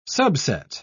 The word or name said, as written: subset